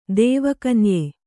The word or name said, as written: ದೇವಕನ್ಯೆ